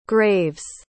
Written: graves

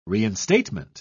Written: reinstatement

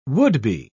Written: would-be

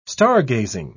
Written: stargazing

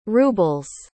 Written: ruble's

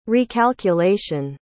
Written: recalculation

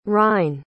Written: rhein

